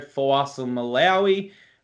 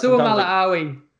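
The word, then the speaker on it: Fa'asuamaleaui: They lose Shandor Earl, Tino Fuasamalawi. I think I said that wrong. Sometimes I get it right. – Fuasamalawi.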